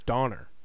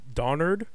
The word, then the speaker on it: Donner: I will say I didn't really know the correct pronunciation of this reindeer's name until I Googled it for this. Donner. – Yeah. – I thought it was Donnard or Dunder.